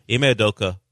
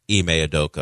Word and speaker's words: Ime Udoka: Ime Udoka, in the middle of a six-game winning streak.